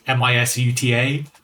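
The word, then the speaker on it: MISUTA (ミスター): So they've just taken the word Mr. and romanized it into Japanese characters, so it's actually like MISUTA, Mr. There's no word like that in Japanese.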